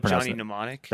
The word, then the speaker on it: Johnny Mnemonic: I don't know if Johnny Mnemonic.